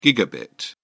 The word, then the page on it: gigabit: British English